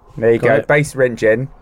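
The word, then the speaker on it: Basse-Rentgen: Rich, my route would start on the small French town of, again, pronunciation issue coming up here, Bas-Rentgen, or Rengen, Bas-Rentgen.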